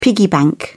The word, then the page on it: piggy bank: British English